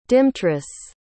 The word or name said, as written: dymtrus